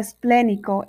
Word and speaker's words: esplénico: Esta palabra esplénico es una palabra estrújula si se acentúa en la antepenúltima sílaba, porque todas las estrújulas se acentúan.